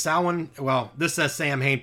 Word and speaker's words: Samhain: It says Samhain, pronounced Samhain, is the third harvest and means summer's end in Gaelic, which is the dark half.